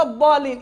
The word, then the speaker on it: الضالين: الضالين اذا هو كيفاش ينطق بالضاد ولا بالظاء بالظاء ما تقوليش خاطر هي في المصحف اتكتبت بالضاد